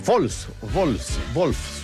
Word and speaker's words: Wolfsburg: No, ese Wolfsburg, porque la W se pronuncia como V corta, ¿no? O V, se dice V.